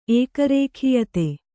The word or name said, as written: ಏಕರೇಖೀಯತೆ